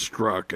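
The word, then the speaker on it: Strzok: By the way, it's, it's spelled Strozik, but I think it's struck actually.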